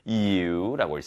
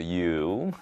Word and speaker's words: ewe: So one day, 그래서 어느 날, after spending all morning trying to catch a naughty ewe, 자, 이거 발음 특이하죠. ewe라고 읽습니다. ewe입니다. 그래서 ewe, 맞죠? 발음이 좀 특이합니다.